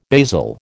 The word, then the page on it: basil: American English